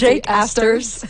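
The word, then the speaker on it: Jack Astor's: Donc c'est après la course au Jacques Astor, je l'ai prononcé vraiment français là, mais je pense Jake Astors.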